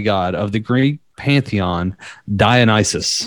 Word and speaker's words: Dionysus: Dionysus. – Dionysus. – Yep. Dionysus. – It sounds Dionysus. Like the devil.